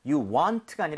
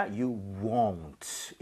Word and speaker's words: won't: 자, 그리고 won't 할 때는 입모양을 많이 모아야 돼요. 그래서 you want가 아니라 you won't.